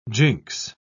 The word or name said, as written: jinx